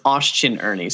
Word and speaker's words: Austin Ernie: Austin at, I can never say your name correctly, Austinian_Ernie? No, that's wrong. – It's Austin Ernie. So like Austin _Ernie.